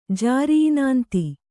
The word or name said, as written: ಜಾರಿಇನಾಂತಿ